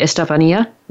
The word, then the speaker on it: Estefania: Let me make sure I'm saying that right. Estefania, right? – Yes, correct.